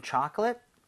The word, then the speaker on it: chocolate: Chocolate 할 때는 앞에 O의 강세가 가죠. 그러면서 입 모양이 좀 벌려지겠죠. – 예. – So 초콜릿보다는 chocolate에 가깝고요. Chip 할 때는 입 모양을 벌리면 안 됩니다. 그러면은 C-H-E-A-P, cheap, 저렴한이라는 뜻이 되니까 그냥 벌리지만 말고 chip.